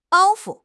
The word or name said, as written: 包袱